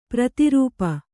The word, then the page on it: ಪ್ರತಿರೂಪ: prati rūpa